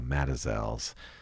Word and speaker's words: Mazatzal: The Matazel Mountains, that's spelled M-A-Z-A-T-Z-A-L. The Tonto Forest website is very clear that the correct pronunciation is Mazatzal. But everybody up in the Rim Country for many decades has called them the Matazels.